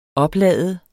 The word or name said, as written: oplade